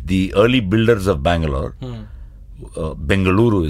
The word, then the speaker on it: Bengaluru: Bangalore was the anglicized version from the British. Bengaluru is the right pronunciation of it.